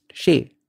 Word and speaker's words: xei: Later on, when the Al-Jabar was being translated into Spanish, the word shay, S-A-Y, which meant thing, was spelled as X-E-I and pronounced shay.